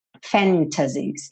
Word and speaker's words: fantasies: What if all these fantasies, fantasies, não tem problema nenhum se você pronunciar o T. Fantasies. Mas é comum, muito comum, especialmente no inglês americano, que esse T suma e aí você tem fantasies.